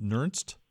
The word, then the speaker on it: Nernst: And Walter, is it Nernst? – That's right.